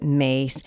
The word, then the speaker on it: Myss: And Carolyn Mace, it's spelled M-Y-S-S, but you pronounce it Mace, is known as a very big spiritual leader.